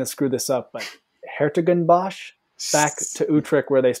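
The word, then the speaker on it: 's-Hertogenbosch: Sertogenbosch.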